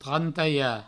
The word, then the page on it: trantalhar: trantaia